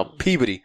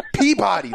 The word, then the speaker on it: Peabody: And I was like, nah, Peabody. He's like, it's pronounced Peabody. That's the spelling. I'm like, nah, dude. If you're from New England, you say Peabody.